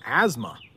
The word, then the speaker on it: asthma: All right, Dwayne is wondering, is there a silent T in asthma? Yeah, I think so. Asthma. Yeah, I think there's a silent T. Not sure why that T is there, but asthma.